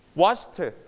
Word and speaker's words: washed: ถ้า ch ไม่ wash แล้วเป็น wash เสียงสั้นนะ wash reach touch when you put ed you also pronounce t again นะ washed